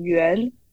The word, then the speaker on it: Yuan: And then my given name, Yuan, actually it pronounces the same as the character of like circle in Chinese.